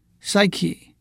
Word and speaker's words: psych: Psyche 作为名词，也是灵魂、精神、心理状态的意思。作为动词，发音是 psych，也会拼成 p-s-y-c-h，是心理上的激发和鼓励的意思。